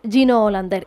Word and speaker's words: Gino Hollander: Bueno, para empezar, cuéntenos quién es Gino Hollander, y si lo he pronunciado bien, que no tengo la seguridad.